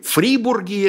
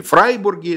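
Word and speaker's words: Fribourg: И поселяются они на территории современной Швейцарии, в городе Фрибурге, если вы произносите по-французски, или Фрайбурге, если вы произносите по-немецки.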